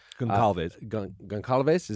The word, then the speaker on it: Goncalves: The four young people died: Madison Mogan, Kaylee Gonzalez, Goncalves. – Is that how that's pronounced? – Yes.